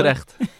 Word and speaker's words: Utrecht: Utrecht.